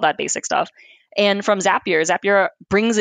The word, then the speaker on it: Zapier: but is it Zapier? – Yeah, Zapier.